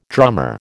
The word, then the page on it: drummer: American English